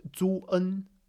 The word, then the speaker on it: zun: So there is an E there, but pinyin drops that E. It's just an oddity of pinyin. So that's why it's ZU in the EN set, but the E drops. But the E is still actually pronounced. Zun, zun, zun, right?